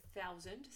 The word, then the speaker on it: thousand: J'en profite d'ailleurs pour faire une petite remarque sur la prononciation de thousand. Ça s'écrit OU au milieu, comme si c'était écrit tout, sauf que ça se prononce bien AOU.